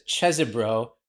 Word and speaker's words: Chesebro: Well, his lawyer says Cheesebro, which means that must be the real pronunciation.